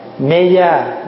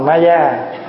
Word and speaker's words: Ma Gia: Ma Gia là mình phát âm theo tiếng Phạn nghe, Mê Gia, nhưng mà mình đọc là Ma Gia, chứ không phải nó trời mẹ Phật tên xấu quá.